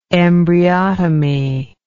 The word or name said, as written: embryotomy